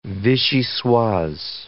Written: vichyssoise

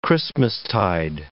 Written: Christmastide

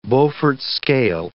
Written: Beaufort scale

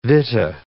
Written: vitta

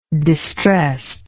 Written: distressed

